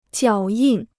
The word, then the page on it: 脚印: jiǎo yìn